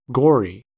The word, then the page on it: gory: American English